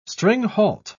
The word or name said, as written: stringhalt